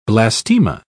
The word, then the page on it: blastema: British English